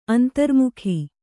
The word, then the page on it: ಅಂತರ್ಮುಖ: antarmukha